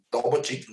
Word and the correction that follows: Djokovic: Ah, Novak Djokovic. Djokovic.